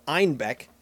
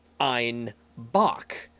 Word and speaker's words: Einbeck: Citizens of Munich pronounced Einbeck, which was the town it's from. So Einbeck as Einbock, billy goat.